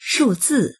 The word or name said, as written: shu4zi4